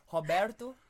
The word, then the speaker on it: Roberto: Herberto? – Your R's, you say it like a H, no? – No, but why'd you say Herberto? O doesn't become an E. Roberto Firmino.